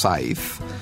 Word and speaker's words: Saiz: It's a Spanish name, and it's pronounced Saez, exactly like the agricultural tool you use to mow the grass. – But it's spelled S-A-I-Z. – S-A-I-Z, yeah.